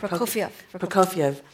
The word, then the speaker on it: Prokofiev: Prokofiev.